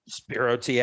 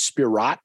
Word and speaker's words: Spiro: I just thought it was Spiro with a silent T on the end. I pronounced Spirot for a while, but then someone told me it was just Spiro.